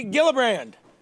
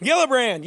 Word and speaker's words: Gillibrand: Gillibrand. If you fucking put the first syllable in the back of your throat, let it go. You can't, Gillibrand. You don't know if I said Hillibrand.